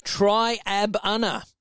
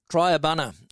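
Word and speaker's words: Triabunna: And I did pronounce it right for Rose. She is at Triab Unna. She's broke it up for the littlies in the class, okay? Triab Unna, and it's Triab Unna.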